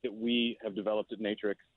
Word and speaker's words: Natrx: Yeah, Natrx.